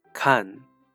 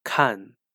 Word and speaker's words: 看: Kan, which means to look, watch, or read, and is normally pronounced and heard in the fourth tone. However, it can also be pronounced as kan, which means to look after.